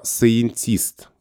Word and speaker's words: scientist: Слово это предлагается писать через дефис и вот именно в формате data не data, а data А есть отдельно слово scientist, именно с таким ударением на и. Scientist. После А буквы и краткая, дальше Е, дальше Н.